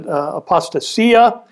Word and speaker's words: apostasia: Now, the falling away, or the Greek term apostasia, or many pronounce it apostasia, apostasia is defined as a defection from truth.